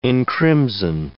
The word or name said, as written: encrimson